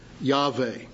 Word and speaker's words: Yahweh: It is the word Jehovah, or as modern scholars pronounce the name, Yahweh.